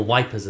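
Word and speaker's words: Ypres: Great historical trip to Yeep. – Yep. – Or Wipers, as they called it in the British slang. – Wipers? – Well, because it's spelled Y-R-P-E-S.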